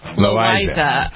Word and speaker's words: Loaiza: Jennifer, the story of Esteban, is it Loaiza? How do you pronounce this guy's name? – Loaiza. – I believe, right.